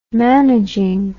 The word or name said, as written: managing